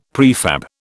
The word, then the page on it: prefab: British English